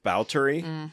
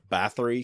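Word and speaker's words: Báthory: Also mixtures of, like, Elizabeth Bowtery, which is, for some people, you might know it as Elizabeth Bathory, because that's how it's spelled.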